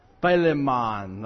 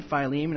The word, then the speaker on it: Philemon: I just remember studying this with my friend, and I'm going through, and I get to this, and I'm like, Philemon. Like, I couldn't pronounce it at all. And he's like, do you mean Philemon? And I was like, that makes so much more sense.